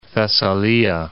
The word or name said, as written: Thessalia